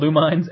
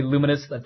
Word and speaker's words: Lumines: I call it Lumines, Lumines. – Spell Lumines. – It's right. But it's got to be Luminous. Thank you, Zombie5, for slapping me around on that so I pronounced it correctly.